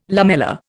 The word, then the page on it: lamella: Indian